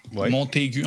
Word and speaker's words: Montagu: Montagu en tout cas M-O-N-T-A-G-U, à prononcer à l'anglaise.